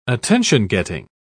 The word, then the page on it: attention-getting: British English